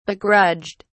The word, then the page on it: begrudged: British English